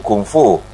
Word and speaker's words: Kung Fu: Kung Fu. Está bien, al menos pronunciado, ¿sí? – Sí, perfecto, perfecto.